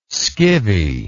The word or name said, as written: scivvy